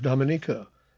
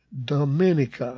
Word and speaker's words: Dominica: The distinctive call sign is J75K, and the operators there, apparently from Italy, pronounce the island Dominica instead of Dominica, like the locals say.